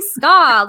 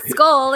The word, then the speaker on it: ska: ska, how do you say ska? Like, it's not skull, it's ska.